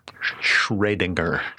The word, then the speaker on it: Schrödinger: Schrödinger. – Okay, so here's that umlaut over the O makes it.